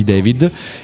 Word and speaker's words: D4VD: E come abbiamo appena spoilerato, parleremo di David, che si scrive, nel caso foste curiosi, D4VD, quindi un'abbreviazione dove il 4 sta per la A di David e la I non c'è, perché David.